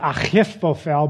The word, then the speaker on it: Ahithophel: It's actually pronounced Ahithophel, but I'll save you guys the guttural because I have to say his name a lot.